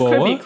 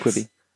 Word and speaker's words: Quibi: and that would be Quobi or whatever. – Quaba? – I think it's Quoboa? Just Quibi, Quick Bites. – Quibi.